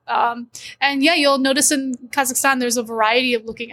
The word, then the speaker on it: Kazakhstan: Say it in syllables. – Kazakhstan, right? – Kazakhstan. – Yeah, you just put the emphasis on the last.